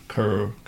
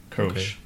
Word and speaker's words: Kölsch: With what is the umlaut sound? – It's like an O-E, a little bit of an R. Kölsch. Kölsch.